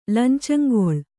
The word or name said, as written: ಲಂಚಂಗೊಳ್